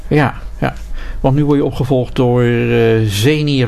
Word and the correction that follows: Zinai: Rosalina. – Zinai Rosalina. – Zinai, moet ik zeggen.